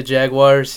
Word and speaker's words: Jaguars: There's no W in that word. Can we just say it right? It's Jaguars. – And you did say he was playing the Jaguars.